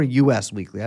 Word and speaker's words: Us Weekly: She's a former gossip journalist for such storied publications like In Touch and is it Us Weekly or US Weekly? I've never known how to pronounce it. – It's Us Weekly.